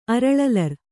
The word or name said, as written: ಅರಳಲರ್